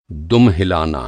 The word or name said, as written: दुम